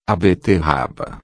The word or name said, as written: beterraba